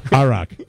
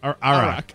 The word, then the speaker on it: Iraq: Iraq. – Iraq.